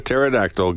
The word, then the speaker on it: pterodactyl: Why can you not hear a pterodactyl going to the bathroom? Because the P is silent.